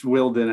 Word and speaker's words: Wilden: It's Wilden, actually.